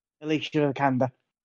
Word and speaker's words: Alicia Vikander: Alicia Vikander.